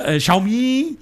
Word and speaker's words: Xiaomi: Ich habe aber dadurch, dass ich mich beim Xiaomi. – Ich weiß leider auch nicht, wie das ist. Ist mir scheißegal, so wird das jetzt ausgesprochen. Xiaomi heißt das, Alter. – Xiaomi? – Xiaomi.